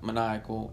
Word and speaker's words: Maniachael: Which, by the way, I'm on Twitch at Maniacal, M-A-N-I-A-C-H-A-E-L.